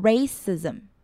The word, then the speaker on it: racism: CI 念起来是念得很短，有 S 的音。然后 S 跟 M 连起来有 Z 的发音。所以合起来念就变成 racism。